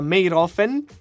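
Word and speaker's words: Mayrhofen: Sì, perché è vicino a, perché sarà fatto a Meyrhofen, questa città austriaca.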